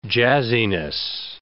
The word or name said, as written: jazziness